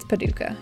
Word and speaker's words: Paducah: Yeah, it's Paducah.